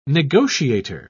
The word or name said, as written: negotiator